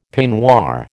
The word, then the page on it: peignoir: American English